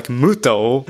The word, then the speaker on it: Muto: Like Muto. M-U-T-O. Like Muto.